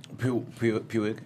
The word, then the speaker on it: Puig: Puig.